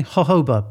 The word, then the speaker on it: jojoba: Well, because we keep saying jojoba, but it's spelt jojoba, isn't it?